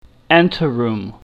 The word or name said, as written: anteroom